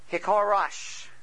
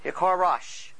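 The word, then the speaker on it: hecharash: In addition, and I want you to write these words down, we have all the, and I'm going to spell it first, H-E-C-H-A-R-A-S-H. H-E-C-H-A-R-A-S-H. – And what is that? – Now that's pronounced as one syllable. Okay? That's Hikarash. Hikarash.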